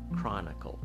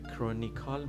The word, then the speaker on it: Chronicle: The Jewish Chronicle Chronicle خب از حالا میخوام برم راجع به هم معنیش صحبت بکنم و اینکه حالا این تفاوت تلفظهایی که ما دیدیم کرونیکال ما اصلا چیزی به این شکل نداریم اصلا در انگلیسی وجود خارجی نداره